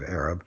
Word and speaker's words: Arab: There is a old-fashioned, sort of low-class pronunciation, Arab. In fact, one of the characters in West Side Story, one of the gangs, has got a nickname of Arab. So it's Arab, not Arab, but Arab.